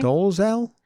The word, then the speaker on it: Dolezal: So Rachel how do you say her last name? – Dolezal? – Dolezal. Dolezal.